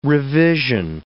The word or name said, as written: revision